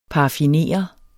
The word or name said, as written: paraffinere